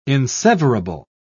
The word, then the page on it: inseverable: British English